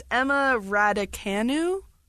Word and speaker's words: Emma Raducanu: So it's Emma Raducanu.